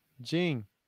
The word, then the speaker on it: Ging: It is spelled with G-I-N-G, but they don't pronounce the G at the end, so. – Jing. – You're over here saying Jing. Like J-I-N-G.